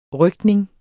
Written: røgtning

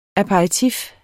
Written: aperitif

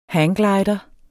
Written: hangglider

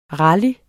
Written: rally

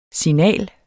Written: signal

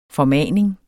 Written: formaning

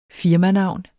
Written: firmanavn

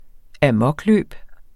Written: amokløb

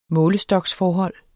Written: målestoksforhold

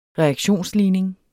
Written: reaktionsligning

